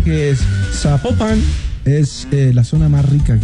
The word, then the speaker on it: Zapopan: Oiga, nos escriben los oyentes y que no se pronuncia Zapopan, sino que se pronuncia Zapopan. – Ah, Zapopan. – Bueno, es Zapopan.